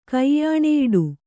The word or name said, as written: ಕೈಯಾಣೆಯಿಡು